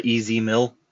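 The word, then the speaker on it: EZ Mil: I have a reaction to a new rapper that Eminem has signed, Easy Meal. Easy Mill. I said that wrong. Easy, literally the letters Easy, and then Mill, M-I-L.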